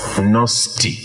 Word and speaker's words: Gnostic: I'll spell it for you because it has a silent G. Okay, so it's Gnostic, I-C at the end, G-N.